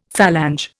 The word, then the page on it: phalange: Indian